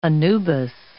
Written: Anubis

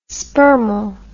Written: spermal

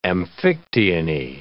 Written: amphictyony

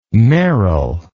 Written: Meryl

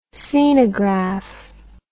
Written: scenograph

